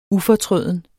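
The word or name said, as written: UFORTRØDEN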